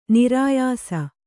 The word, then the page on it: ನೆರೆಯಿಸು: nereyisu